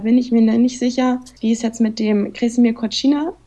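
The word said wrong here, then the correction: Kozina: der so ausgesprochen? Kosina heißt er, ja, genau.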